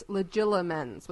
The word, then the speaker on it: Legilimens: We're all pronouncing this wrong. It's legilimens with an L. – Legilimens? – Legila, read. From the Latin legere, read.